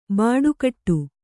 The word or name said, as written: ಬಟ್ಟೆಗೆಡು